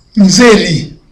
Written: nzeri

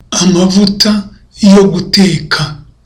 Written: amavuta yo guteka

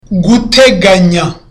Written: guteganya

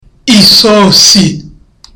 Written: isosi

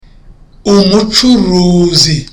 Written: umucuruzi

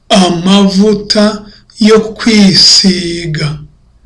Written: amavuta yo kwisiga

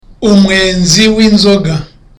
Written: umwenzi

